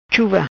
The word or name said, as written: Chuva